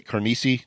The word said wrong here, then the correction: Canarsie: Did I say it right? – Canarsie. – Now you make me think. Canarsie, sorry.